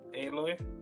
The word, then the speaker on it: Aloy: Yeah, how do you pronounce her name? – Aloy. – How about you, games? – I mean, they say it in the game, so yeah. I before I knew it was Aloy. They do? – Yeah, a few different characters.